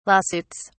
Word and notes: lawsuits: British English

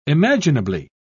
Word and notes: imaginably: British English

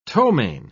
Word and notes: ptomain: British English

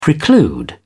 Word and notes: preclude: British English

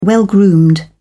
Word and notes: well groomed: British English

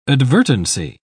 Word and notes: advertency: British English

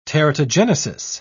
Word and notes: teratogenesis: British English

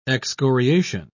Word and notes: excoriation: British English

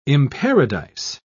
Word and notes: imparadise: British English

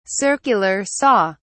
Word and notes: circular saw: British English

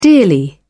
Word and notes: dearly: British English

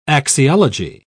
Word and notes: axiology: British English

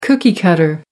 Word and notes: cookie-cutter: American English

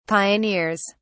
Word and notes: pioneers: British English

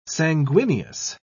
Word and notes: sanguineous: British English

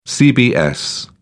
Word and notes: cbs: British English

